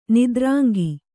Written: ನಿದ್ರಾಂಗಿ

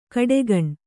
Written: ಘಟಾಗ್ನಿ

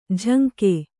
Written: ಜಂಗೆ

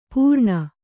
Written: ಪೂರಣ

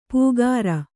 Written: ಪೂಗಾರ

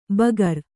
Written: ಬಗರ್

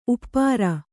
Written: ಉಪರಿ

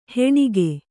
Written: ಹೆಣಿಗೆ

